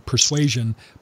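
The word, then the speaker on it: Pre-Suasion: The other's called Persuasion. I didn't mispronounce that. We think of the word persuasion, but the book is titled Persuasion, P-R-E, Suasion.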